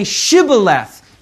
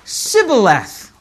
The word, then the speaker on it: Shibboleth: Now, for some reason, because of a certain dialect in those days, there was a certain group of people that was unable to say Shibboleth. They said Sibboleth.